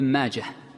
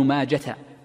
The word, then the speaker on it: ماجه: أيضا من الفوائد تتعلق بهذه الجملة من كلام المصنف قوله ابن ماجه، والمشهور عند المحدثين أن الهاء في ابن ماجه هاء ساكنة، وكثير من الناس يجعلها تاء مربوطة فيقول روى ابن ماجة، وهذا المحدثون في نطقهم لا يصححون هذا الشيء.